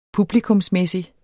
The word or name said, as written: publikumsmæssig